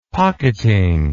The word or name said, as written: pocketing